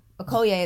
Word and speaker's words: Okoye: Okoye is how you say it, her name, that was in the movie. – Okay. – Danai's character, the general. That's how you say her name.